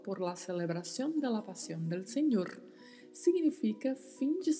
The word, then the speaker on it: pasión: Pasión que é um substantivo que significa paixão. E atenção para a pronúncia aí, que é som de S mesmo, tá? Pasión.